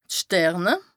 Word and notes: d' Sterne: Bas-Rhin; Strasbourg

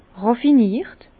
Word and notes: ràffiniert: Bas-Rhin; Herrlisheim

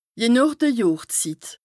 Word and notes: je nooch de Johrszit: Bas-Rhin; Strasbourg

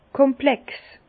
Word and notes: komplex: Bas-Rhin; Herrlisheim

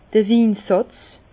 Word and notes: d'r Winsàtz: Bas-Rhin; Herrlisheim

